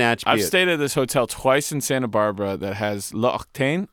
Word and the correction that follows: L'Occitane: Is that how you say it? – L'Occitane. – L'Occitane.